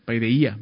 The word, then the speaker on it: paideia: Paideia, más bien, se pronuncia así en griego.